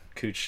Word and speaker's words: Couch: So I guess to end this, any tourists coming to Portland, it's pronounced cooch here.